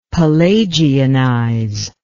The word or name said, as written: pelagianize